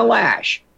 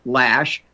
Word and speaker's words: Loesch: And I've been mispronouncing her name. Shame on me. Let me mute that video. And Dana Lash, folks. Dana Lash, spelled L-O-E. Let's see here. Let me mute this one. Spelled L-O-E-S-C-H.